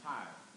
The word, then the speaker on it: Tyre: He is in the city of Tyre, or it's pronounced Tyre.